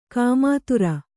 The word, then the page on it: ಗೋಮೂತ್ರ: gō mūtra